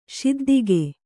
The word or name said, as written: ಶಿದ್ದಿಗೆ